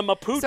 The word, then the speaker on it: Mapuche: The Mapuche. So wait, can you spell it? M A P U C H E.